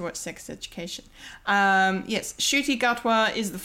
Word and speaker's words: Ncuti Gatwa: Um, but it's actually pronounced Ncuti Gatwa. Not yeah. yeah, not the way I not the way we all said it last week because we were like we've never heard of this person.